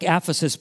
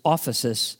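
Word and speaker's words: ophesis (ἄφεσις): It looks like aphesis, but it's actually pronounced ophesis.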